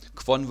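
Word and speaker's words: Kwon: Quon wird der Mann eigentlich ausgesprochen, aber Kay Von finde ich dann ziemlich überragend und äh, ja, der wird jetzt nur noch von mir so genannt.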